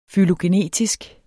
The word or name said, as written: fylogenetisk